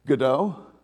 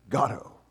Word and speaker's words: Godot: By the way, it's often pronounced in English, Godot, and Beckett himself said, that's completely wrong. It is Godot. Because Godot sounds like bozo.